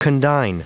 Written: condign

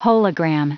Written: hologram

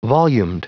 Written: volumed

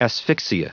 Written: asphyxia

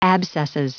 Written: abscesses

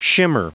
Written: shimmer

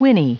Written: whinny

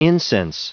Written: incense

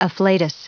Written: afflatus

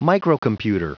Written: microcomputer

